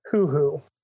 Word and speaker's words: huhu: I think this is pronounced hoo-hoo. – Okay, can I get a spelling? – H-U-H-U.